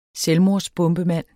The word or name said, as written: selvmordsbombemand